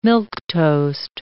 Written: milquetoast

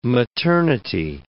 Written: maternity

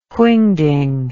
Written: whingding

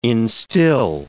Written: instil

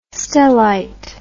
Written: stellite

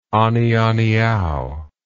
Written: anianiau

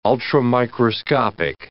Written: ultramicroscopic

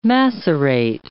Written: macerate

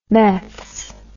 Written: meths

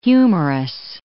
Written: humerus

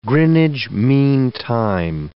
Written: Greenwich mean time

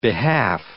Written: behalf

